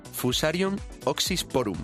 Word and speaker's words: Fusarium oxysporum: El protagonista de esta historia es una cepa de, a ver si lo digo bien, Fusarium oxysporum. – Muy bien, perfecto.